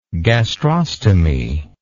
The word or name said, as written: gastrostomy